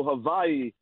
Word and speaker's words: Hawaii: out here from the beautiful island of Honolulu, Hawaii. And I just wanted to say that I'm backing you up. That that is how they pronounce it out here. They pronounce their W's with a V.